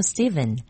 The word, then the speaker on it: Stephen: 예를 들어 스티븐, S-T-E-P-H-E-N. 스펠링이 PH인데 발음은 V으로 발음이 나죠. 스티븐.